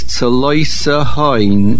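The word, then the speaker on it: Tzaloisahoin (צלותהון): The word is Tzaloisahoin. There is a Shivana under the Sof. Most people swallow this word, and you must say it slowly to say it correctly.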